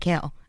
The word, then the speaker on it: Chaol: It's spelled C-H-A-O-L, by the way. – Kale? Charcoal? – Chol. I want to say Chol so bad.